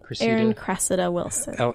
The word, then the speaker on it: Erin Cressida Wilson: Erin Cressida Wilson.